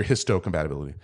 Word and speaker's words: histocompatibility: They stand for major histone compatibility. – Histone? – Yeah. – Okay. – Or major histocompatibility.